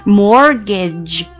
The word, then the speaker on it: mortgage: 就是按揭这个单词 Mortgage M-O-R 有一个 T 在那儿，我永远都忘记写这个字母 Mortgage，因为这个 T 不发音 Mortgage M-O-R-T-G-A-G-E Mortgage。